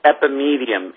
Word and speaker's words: epimedium: then what you want to do is you want to find stuff with an ingredient called epimedium in it.